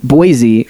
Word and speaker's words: Boise: I was trying to drink some of this delicious Dagger Falls IPA brewed right here in Boise, or at least Idaho. – It's Boise.